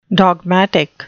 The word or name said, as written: dogmatic